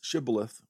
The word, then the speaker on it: shibboleth: In the Bible, in the book of Judges, there was one tribe, the Gileadites, who could pronounce shibboleth with the SH sound.